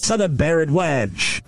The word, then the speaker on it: Bharadwaj: The name from someone involved in the story is Suda, S-U-D-H-A, and then some last name with a lot of weird letters. B-H-A-R-A-D-W-A-J. I would pronounce that Bharadwaj. Suda Bharadwaj. Let's see how I pronounce it. You know, I mean, virtual. How does virtual pronounce this? – Suda Bharadwaj.